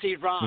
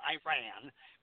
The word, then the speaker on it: Iran: It's Iran, mm-hmm. – by the way, not Iran, but I'm a stickler on pronunciations.